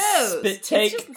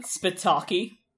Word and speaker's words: spit take: I used to pronounce spit take spitaki to rhyme with bukkake, because I had only ever seen it written. So, like, the first time I heard somebody say spit take, I was like, what is that?